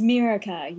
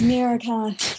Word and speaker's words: Miraka: I do kind of realize now it sounds like Morocco. – It's Miraka, please.